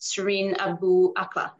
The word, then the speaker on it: Shireen Abu Akleh: Shireen Abu Akleh, yep.